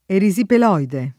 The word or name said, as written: erisipeloide